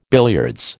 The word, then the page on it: billiards: American English